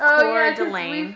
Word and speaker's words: Coeur d'Alene: It's pronounced Coeur d'Alene.